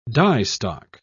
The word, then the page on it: diestock: British English